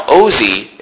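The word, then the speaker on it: Osee: Now Ozi is the New Testament pronunciation of Hosea.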